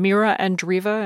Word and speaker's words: Mira Andreeva: And what about, in the just minute we have left, this 16-year-old Russian, Mira Andreeva?